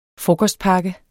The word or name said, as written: frokostpakke